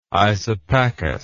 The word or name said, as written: isopachous